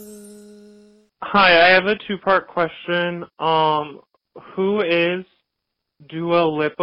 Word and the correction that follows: Dua Lipa: But as you said her name incorrectly twice, Lindsay was like, nope. It's Dua Lipa. – It's Dua Lipa.